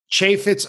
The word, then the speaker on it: Chaifetz: And that limb by limb is from August 28th, 2012, at I recently learned Chaifetz Arena is how you pronounce the venue in St. Louis.